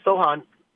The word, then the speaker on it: Cochan: That would be, you can correct me if I say this wrong, it's spelled C-O-C-H-A-N. Sohan, is that how you say it? – Sohan.